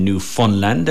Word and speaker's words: Newfoundland: We'll be talking about the UNESCO bid that Valencia are doing with Hearts Content in Newfoundland. And it's Newfoundland, not Newfoundland. That's how they pronounce it, Newfoundland, in Canada.